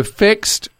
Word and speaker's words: FIXD: All right, F-I-X-D it's spelled. So the Fixed